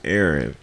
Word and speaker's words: Aaron: I don't like being called Aaron, but if I never correct somebody and say, "Hey, the way my name is pronounced is Aaron," they would assume that the way they pronounced it that doesn't work is correct for what you're trying to do.